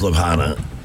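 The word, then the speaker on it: Ljubljana: L-J-A-N-A. – Lejublahana. Lejublahana. – Mekaleka hi, Mecca. – Lejublahana. – It's Lubhana. – Lubhana? – The J is silent, yes.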